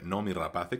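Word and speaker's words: Noomi Rapace: Igbar, que está casado con María, nombre muy normal, que son los padres del niño oveja, interpretada por no, no, vamos a intentar decirlo simplemente como se escribe Nomi Rapace,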